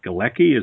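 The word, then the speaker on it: Gilaki: It's kind of a warm climate, I noticed, and not just Farsi is spoken there, but another language is spoken called Galeki, is that right? – Exactly, Galeki.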